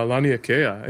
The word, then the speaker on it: Laniakea: Oh, Lanai, yeah. – is that how you pronounce it? – Uh, Laniakea.